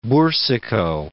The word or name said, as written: Bourcicault